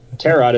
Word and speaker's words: tarot: Tarot is pronounced without the T sound at the end.